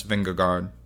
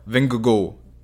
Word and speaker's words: Vingegaard: Compare two cycling Danes, Mads Pedersen and Jonas Vingegaard. Or Vingegaard. That was my mint Danish pronunciation there for the second version of Jonas's last name.